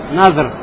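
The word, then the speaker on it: назр (نذر): То, что слово «обет» читается как «назр», то есть «сукуном». Не «назар», а как «назр» – «сукуном».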